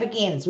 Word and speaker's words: Beguines: Yeah, Beguines.